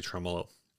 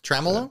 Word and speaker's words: tremolo: So I've been thinking about early on, you know, we were frequently lambasted for not knowing how to say tremolo. – Mm. Tremolo? – Tremolo. – Tremolo? – Well, because I think we both said tremolo. Like, we just moved the emphasis to the wrong syllable.